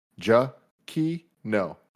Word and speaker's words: Giacchino: His Twitter in his bio says, my last name is pronounced Giacchino. So Giacchino.